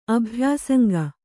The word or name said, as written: ಅಭ್ಯಾಸಂಗ